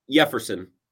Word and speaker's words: Jefferson: But their top rated catching prospect ever, I think, is probably a 2025 debut guy with Jefferson Carroll. But anyway, Jefferson. By the way, it's a soft J.